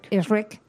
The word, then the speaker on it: Ehrmann: Pues mira, Max Ehrmann, bueno, no sé si lo he dicho bien. – Max Ehrmann. – Ehrmann. – Como el monstruito verde, exacto.